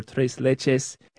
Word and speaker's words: tres leches: She felt that she could do the recipe for tres leches gelatina con mango.